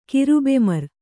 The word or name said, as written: ಕಿರುಬೆಮರ್